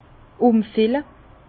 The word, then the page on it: umfille: Bas-Rhin; Herrlisheim